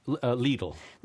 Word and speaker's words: Lidl: And, well, I never heard of this German grocer, this Lidl. Mm-hmm. How do you pronounce this? – Lidl. – Lidl, okay.